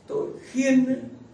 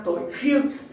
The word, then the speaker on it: tội khiên: Cho nên ở cái kinh chúng ta đọc ở Bắc là xin Chúa tha tội, tội khiên, xin tha tội khiên. Thì trong Nam anh không đọc là tội khiên đâu, xin ta tội khiêng. Nam quá.